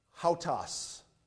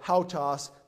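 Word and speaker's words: hautas (οὕτως): It looks like an O-U-T with a W-S. I know it looks like something different than hautas, but that's how you say it in Greek. That repetition of hautas, the word hautas means after this manner or on this wise or so.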